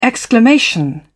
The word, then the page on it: exclamation: British English